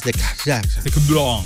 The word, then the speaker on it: Decathlon: Sí, Decathlon. Es francés, Decathlon. – Decathlon. Yo qué sé, da igual. Decathlon, tío, pero si Decathlon.